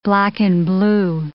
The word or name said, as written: black-and-blue